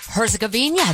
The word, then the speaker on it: Herzegovina: Did you say Herzegovina? – Herzegovina. That's how you say it.